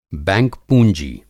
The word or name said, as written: बैंक पूँजी